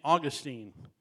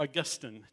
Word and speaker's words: Augustine: One is a guy named St. Augustine, or Augustine, depending on how you want to pronounce it.